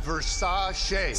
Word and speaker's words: Versace: Pronounced Versace.